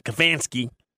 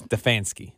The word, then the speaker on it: Stefanski: But that scares me because it sounds, and I want to say his name disrespectfully. That's why I said Stefanski. – Like Botanical Garden. – Botanical. – It's Stefanski.